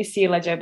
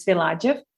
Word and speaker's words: Silajev: So her name is Sandra Silajev. I thought it was maybe Silajev, but it's Silajev, I think. – Silajev, okay. – On the second syllable.